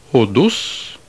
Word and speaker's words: hodos: The next example of the acute accent over a short vowel is the Greek word for street, which has an acute accent on the last syllable. Hodos.